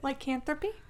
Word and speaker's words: lycanthropy: And at some point prior to the events of the book, he became cursed by lycanthropy. How do you say it? – Lycanthropy? – He became cursed with lycanthropy.